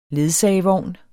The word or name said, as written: LEDSAGEVOGN